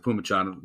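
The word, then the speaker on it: Phommachanh: I think you pronounce it Pumachon. It looks like Fomachon.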